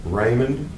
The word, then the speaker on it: Raymond: Raymond, R-A-Y-M-O-N-D. Last name, Palladino, P-A-L-A-D-I-N-O.